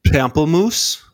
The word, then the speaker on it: pamplemousse: It's a pamplemousse, I think is how you say it.